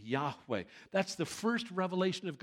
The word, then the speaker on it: Yahweh: Number one, there is no J or V in the Hebrew alphabet. It is impossible to pronounce God's name Jehovah. We do because of the influence of the German language upon the English culture. That's the only reason we pronounce it there. There's no J or V in it.